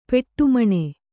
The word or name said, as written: ಪೆಟ್ಟೆಮಣೆ